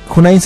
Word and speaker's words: Hoonnine: Pueden adquirir los boletos en la página de Junain. Junain se escribe H-O-O-N-N-I-N-E, Junain.